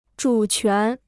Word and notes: 主权: zhǔ quán

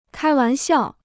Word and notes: 开玩笑: kāi wán xiào

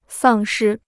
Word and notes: 丧失: sàng shī

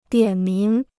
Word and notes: 点名: diǎn míng

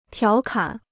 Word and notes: 调侃: tiáo kǎn

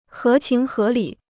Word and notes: 合情合理: hé qíng hé lǐ

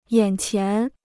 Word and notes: 眼前: yǎn qián

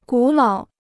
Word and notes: 古老: gǔ lǎo

